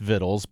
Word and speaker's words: victuals: It's pronounced vittles, but when we hear vittles, we think about, like, the cowboys eating their beans around the campfire, right? – Yep.